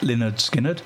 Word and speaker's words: Lynyrd Skynyrd: Coming up next, a less commonly played track from Lynyrd Skynyrd, and this is pronounced Lynyrd Skynyrd, from 1973, would you believe, and this is Simple Man.